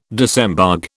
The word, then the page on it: disembogue: British English